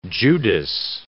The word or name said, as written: Judas